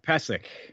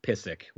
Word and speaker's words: Pysyk: P-Y-S-Y-K. Come on. – Mark Pesek. – All right. Thanks. Jeez. – I know you want to say it Pissick, which I think some people...